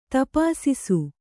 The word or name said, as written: ತಪಾಸಿಸು